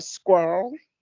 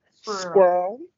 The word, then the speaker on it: squirrel: And also, side note, can I say I'm like obsessed with the way some Americans pronounce squirrel. It just, I love it. It's almost like squirrel. So it's like one syllable instead of two. Squirrel.